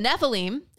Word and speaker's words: Nephilim: Then we have verse 4, the Nephilim. Am I pronouncing that right? Is that the transliteration? – Are.